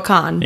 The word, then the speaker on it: Kahan: It's Noah Kahan, right? – Noah Kahan. – Yeah. – Yeah. – No Kahan, but it's spelled like that. Yeah. So it's confusing.